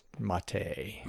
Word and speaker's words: MATE: I'm going to call it Mate because, you know, this, I'm a Southern American, and, you know how we are. We mispronounce everything. – Yeah, that's all right. Hey, it's you're pronouncing it the way it's spelled, and that's okay.